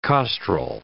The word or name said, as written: costrel